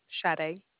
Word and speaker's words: Sade: Sade. – Spell that for me. – It's S-A-D-E. – S-A-D? – E.